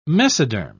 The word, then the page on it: mesoderm: British English